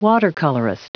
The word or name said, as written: watercolorist